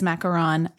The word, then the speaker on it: macaron: But yes, technically it is macaron. It is spelled with one O, M-A-C-A-R-O-N. And then you have the macaroon, which is technically the meringue and coconut confection that's usually like dipped in chocolate. That has two O's.